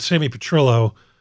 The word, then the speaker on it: Sammy Petrillo: You're talking about Sammy Petrillo. That's how it's pronounced, right? – Yeah.